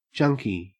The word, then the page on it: junky: Australian English